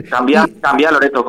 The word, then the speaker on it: Cambiá: Cambiá Loreto, con acento en la A.